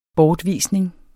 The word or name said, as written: bortvisning